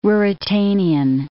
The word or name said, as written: Ruritanian